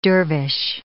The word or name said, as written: dervish